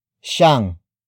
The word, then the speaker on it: siyang: Word for word it's huwag siyang babalik.